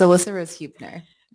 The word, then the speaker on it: Alyssa Rose Huebner: Yes, it's Alyssa Rose Huebner.